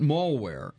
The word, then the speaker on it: malware: I pronounced it malware. He said, "You pronounce malware like it has two L's, like mall or hall, instead of one, as in pal or how or gal or balcony or California.